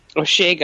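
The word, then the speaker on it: Osheaga: I think you pronounce it Oshiega. – Oshiega. Yes, yeah. – It's, isn't that not right? – August. No, it's Oshiega, but it's fine.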